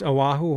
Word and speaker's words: Oahu: It's Oahu, Hawaii.